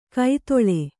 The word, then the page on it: ಕೈತೊಳೆ: kaitoḷe